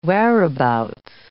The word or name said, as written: whereabouts